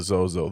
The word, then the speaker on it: Zozos: And lo and behold, how do you pronounce Z-O-Z-O-S? Zozo.